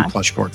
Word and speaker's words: Pleschourt: Close, but no, it's Pleschourt. – Okay, that was my second one, but I got a little fancy with that CNH, you know, like in the Italian language, it's bruschetta. The CH is not like children or court. Well, in your case, court is the H is silent, but thank you.